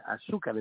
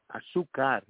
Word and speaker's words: azúcar: It was azúcar, and azúcar, it should mean sugar. But azúcar is the way you pronounce it, but she pronounced it like Afro-Cuban, azúcar.